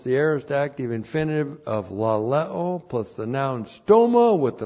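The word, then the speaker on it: laleo (λαλέω): L-A-L-E long O, laleo.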